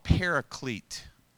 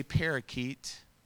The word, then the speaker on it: paraclete: Well, there's this word, and it comes from a Greek word um, it's called paraclete. I didn't say parakeet, I said paraclete.